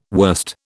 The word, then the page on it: wurst: British English